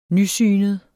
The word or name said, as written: nysynet